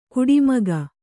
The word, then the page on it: ಕಾಡುಮಿಗ: kāḍu miga